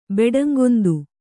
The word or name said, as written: ಬೆಡಂಗೊಂದು